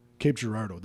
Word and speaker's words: Cape Girardeau: Is it Cape Girardeau? How do you say it? – Cape, you got it exactly right. – Cape Girardeau.